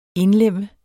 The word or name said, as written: indlemme